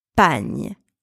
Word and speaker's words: pagne: All right, so next syllable, we have the letters P, A, G, N, E. And those letters are pronounced pagne, pagne.